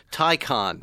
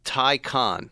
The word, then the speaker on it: Taycan: Taycan. Tie like a tie like you're wearing and a con like you are as well. Taycan. – I thought it was a Taycan. – Pronounced, confusingly, Taycan.